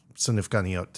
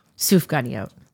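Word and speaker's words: sufganiyot (סופגניות): I don't think I actually really knew about sufganiyot till sufganiyot. – Yeah, see, I don't even say it right.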